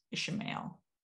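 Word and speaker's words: Ishamael: Ishmael. That's how they pronounce it here.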